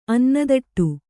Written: ಅನ್ನದಟ್ಟು

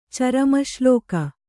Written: ಚರಮಶ್ಲೋಕ

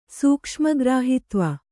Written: ಸೂಕ್ಷ್ಮಗ್ರಾಹಿತ್ವ

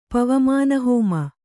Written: ಪವಮಾನಹೋಮ

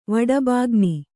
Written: ವಡಬಾಗ್ನಿ

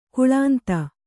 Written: ಕಾಲಿಂದಿ

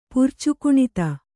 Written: ಪುರ್ಚುಕುಣಿತ

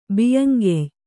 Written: ಬಿಯ್ಯಂಗೆಯ್

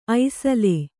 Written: ಐಸಲೆ

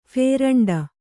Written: ಫೇರಂಡ

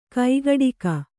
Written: ಕೈಗಡಿಕ

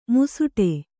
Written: ಮುಸುಡೆ

